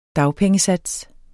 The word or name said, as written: dagpengesats